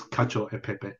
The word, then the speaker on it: cacio e pepe: It's cacio e pepe, which I believe is cacio e pepe.